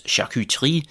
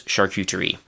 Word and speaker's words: charcuterie: So, the French pronunci- pronunciation is charcuterie, something like that. Hopefully, my French pronunciation has not decayed too much in the past several years since I've spoken it regularly, but the English pronunciation is actually, again, pretty close charcuterie.